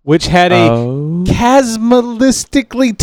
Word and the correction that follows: cataclysmically: terrible nuclear war thousands of years before the show arrived. – Cataclysmically? – Yes, that's the way to properly pronounce it.